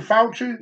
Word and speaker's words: Fauci: Every time one of y'all started complaining about that doctor, how you say his name, Fauci? Yep, you got it.